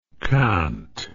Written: quinte

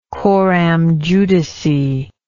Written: coram judice

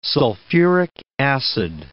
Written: sulfuric acid

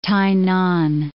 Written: T'ai-nan

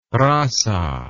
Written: raza

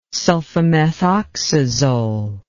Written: sulfamethoxazole

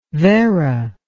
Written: vera

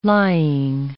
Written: lying

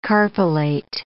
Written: carpellate

